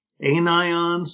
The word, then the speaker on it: anions: In chemistry, a salt is a chemical compound consisting of an ionic assembly of cations and anions. Now, I think those are the correct pronunciations. Cations spelled like cat plus ions, anions spelled like an plus ions, and looking a lot like the word onions.